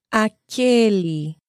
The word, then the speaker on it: aquele: Masculine form is aquele, closed. And the feminine, aquela. It's opened.